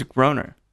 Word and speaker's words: Groner: So my last name's Groner. – Okay. – Which is a terrible last name. It rhymes with boner.